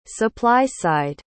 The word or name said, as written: supply-side